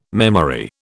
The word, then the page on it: memory: British English